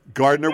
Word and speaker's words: Gardiner: Gardiner with a hard G. We're pronouncing it correctly.